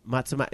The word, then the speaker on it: Matsumae: Manami Matsume. Do you guys know Manami Matsume? – Matsumae. – Did I pronounce that wrong? Matsumae.